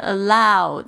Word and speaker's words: allow: 这个 allow 这个呢，应该是。嗷嗷，这两个双母双元音的这个发音呢，所以你一定要发的很全。